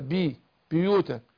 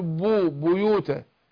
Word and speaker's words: بيوتا: خد بالك من كلمة بيوتا ديت، لأن كتير من القراء بيغلطوا في ينطق الباء المضمومة في أولها ينطق قريب الكسر، بيوتا بي بيوتا، لكن يقول بو بيوتا بو يو والياء، يعني هضم الباء والياء مع بعض، أو ما أقولش بيوتا بيو، لا بويو.